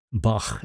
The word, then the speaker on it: Bach: And our good old friend, Johann Sebastian Bach, Bach as it's actually pronounced, who died at the same year we're considering the Baroque era to have ended, 1750.